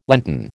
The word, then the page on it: Lenten: American English